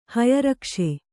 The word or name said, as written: ಹಯರಕ್ಷೆ